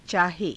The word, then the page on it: caahe: hindi